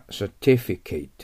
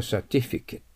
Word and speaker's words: certificate: 動詞は certificate、名詞は certificate。はい、そこ書かれてませんけど、発音記号気をつけてくださいね。